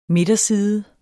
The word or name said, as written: midterside